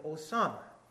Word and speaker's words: Osama: And one of the reasons we went to war was because of Osama, which rhymes with Obama.